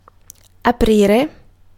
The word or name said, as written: open